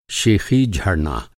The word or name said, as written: शेख़ी